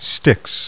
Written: Styx

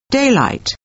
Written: DAYLIGHT

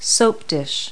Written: soap dish

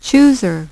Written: chooser

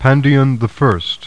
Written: PANDION

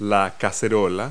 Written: saucepan

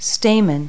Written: stamen

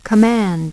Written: command

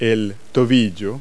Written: ankle

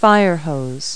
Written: firehose

